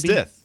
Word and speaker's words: Stith: Stith.